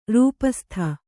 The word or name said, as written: ರೂಪಸ್ಥೆ